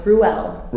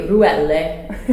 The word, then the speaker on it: Ruelle: It's Ruelle. – Ruelle, okay. – Although here in Italy, Ruelle.